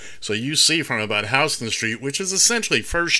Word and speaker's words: Houston: The original city of New York, south of Houston Street, and yes, we pronounce it Houston, although it's spelled Houston.